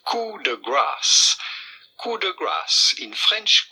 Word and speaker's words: coup de grâce: Do not pronounce the P. Do not say coup, but rather coup de grâce.